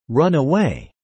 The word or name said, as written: run away